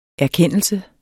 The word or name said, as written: erkendelse